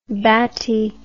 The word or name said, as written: batti